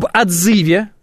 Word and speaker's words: отзыве: А, об отзыве, это правильное ударение, сразу вам всем скажу, не надо меня исправлять.